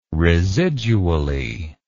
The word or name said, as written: residually